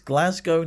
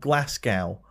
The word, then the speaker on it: Glasgow: And thank you for pronouncing it correctly. It's Glasgow, not Glasgow.